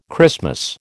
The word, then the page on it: Christmas: American English